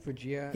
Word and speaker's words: Fujiya: Fuya and Miyagi, right? – Fujiya.